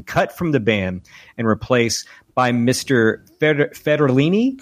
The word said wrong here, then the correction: Fridianelli: Fridianelli is how I pronounce it.